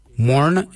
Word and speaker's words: moorn: Yunt is spelled Y-O-O-R-N-D. And the last colour is mourn. Mourn is black. Mourn is spelled M-O-O-R-N.